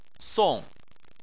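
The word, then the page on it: song4: tone 4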